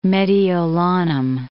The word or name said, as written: Mediolanum